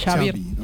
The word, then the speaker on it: Xavi: Xavi. – Yeah. Xavi like chocolate. – Yeah. Okay. Xavi como el chocolate.